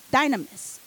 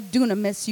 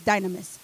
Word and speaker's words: dynamis: Now that word power is Strong's G1411, which is dynamis. Or dunamis, you can pronounce it that way as well, but in the Greek it's dynamis.